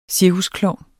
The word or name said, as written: cirkusklovn